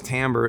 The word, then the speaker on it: timbre: There are some who describe, there's a word in music, which is timbre, which is spelled timber, sort of, but it's timbre, trust me, T-I-M-B-R-E.